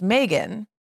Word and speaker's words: Meagan: Now, one thing I also think is interesting is that my name was intended to be pronounced Megan.